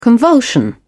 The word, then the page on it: convulsion: British English